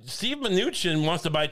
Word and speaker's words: Mnuchin: I believe it's Mnuchin. – Mm. – You didn't know how to say it. Mnuchin? Mnuchin is it. Mnuchin.